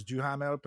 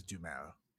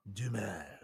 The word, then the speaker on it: Duhamel: That almost got me, because I thought it was Duhamel, but then I know it is Duhamel. The H is silent. Duhamel.